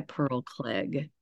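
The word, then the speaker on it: Pearl Cleage: It's titled Mad at Miles by Pearl Klig. I've been told that's how to say her name.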